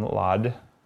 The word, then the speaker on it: laude: And she studied nutrition and graduated magna cum laude. How do you say that? – Laude, there you go.